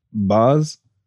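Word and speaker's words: Bas: Boz, how do you spell that? – It's B-A-S.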